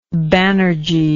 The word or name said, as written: Banerjea